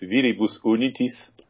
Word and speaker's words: Viribus Unitis: And it's your name is Verbis Unitas. Is that the correct pronunciation? – In Latin, it's Viribus Unitis.